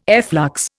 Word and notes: efflux: Indian

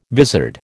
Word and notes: vizard: American English

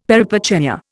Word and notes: peripeteia: Indian

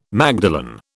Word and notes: magdalen: British English

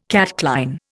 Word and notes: catchline: Indian

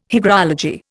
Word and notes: hygrology: Indian

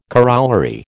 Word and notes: corollary: American English

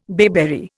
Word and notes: bayberry: Indian

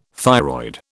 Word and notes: thyroid: British English

